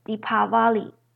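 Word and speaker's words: Deepavali: 迪瓦里呢，有人念作迪巴里 V 的音，或是迪帕瓦里。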